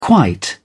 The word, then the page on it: quite: British English